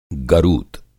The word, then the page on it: गरुत: Hindi